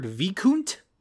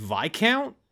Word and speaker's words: Viscount: Speaking of mispronunciation, the bartender of the inn that they were at, he pronounced the word Vicount and the word's pronounced Viscount. And like literally every other character in the show pronounces it correctly. All of them say the word Viscount correctly. It's just this one asshole who's pronouncing it Vicount.